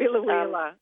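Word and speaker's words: Biloela: Now, it's Bilalia. Is that how you pronounce it? The Queensland... – Bilalia. – Yeah, Bilalia, yeah.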